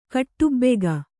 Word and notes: ಖಟಬಾಕಿ: khaṭabāki

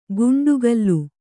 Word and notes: ಗುಂಡುಗಲ್ಲು: guṇḍugallu

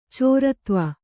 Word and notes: ಚಾರುತ್ವ: Kannada

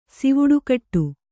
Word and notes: ಸಿವುಡುಕಟ್ಟು: sivuḍu kaṭṭu

